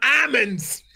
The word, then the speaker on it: almonds: You stole an L from almonds.